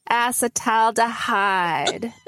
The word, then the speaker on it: acetaldehyde: Then you say, hey, dog, can I get you to acetaldehyde? acetaldehyde. – I love it. Thank you. I will never forget that pronunciation now.